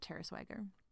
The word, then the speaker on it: Tara Swigger: And it's actually spelled Tara Swigger. I'll link to it in the show notes, but she pronounces it Tara Swagger.